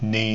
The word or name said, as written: ni